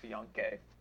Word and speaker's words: fiancé: Fiance. – I can't remember what it was, but it's pronounced fiancé.